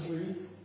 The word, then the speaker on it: wreath: It's wreath, right? Yeah. Okay. Same as breath is called, it's pronounced breath. This is called wreath.